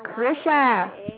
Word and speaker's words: Krysia: Okay, and the lucky winner is Chrissia. I don't know if I'm saying that right. K-R-Y-S-I-A. – Chrissia.